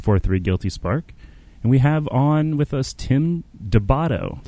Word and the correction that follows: Dadabo: Actually, Reclaimer, it's pronounced Dadabo. – Dadabo.